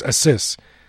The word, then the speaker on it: Asus: Someone told me it's pronounced like Pegasus, so it's Asus.